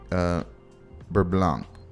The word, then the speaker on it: beurre blanc: So it's poached egg, charred spring onions, and poblano beurre blanc.